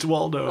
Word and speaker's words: Waldo: Because we said his name wrong. When we did the episode, we kept calling him Walter. Sorry about that, Waldo. – Was it really Waldo? – It's Waldo.